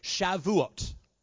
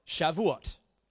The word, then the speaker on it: Shavuot: But it's Shavuot. Shavuot. S-H-A-V-U-O-T.